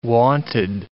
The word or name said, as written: wonted